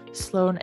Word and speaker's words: Sloan: Sloan is spelled S-L-O-A-N.